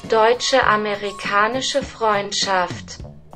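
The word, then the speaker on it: Deutsch Amerikanische Freundschaft: Deutsche-amerikanische Freundschaft.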